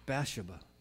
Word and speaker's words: Bathsheba: This is the story of David and Bathsheba, or Bathsheba, if you want to say it correctly, Bathsheba.